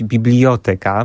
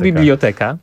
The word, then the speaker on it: biblioteka: Więc moja taka hiperfiksacja, znowu coś mamy wspólnego na punkcie akcentu, doprowadziła do tego, że ja zacząłem wymawiać biblioteka, mimo że to nie jest słowo na ika. – Bibliotyka jeszcze. – Właśnie. – Chyba. Też jest chyba takim słowem, które też jest nadmiernie. – Po prostu biblioteka.